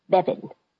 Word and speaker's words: Beavan: I spell it B-E-A-V-A-N, but I pronounce it Bevan.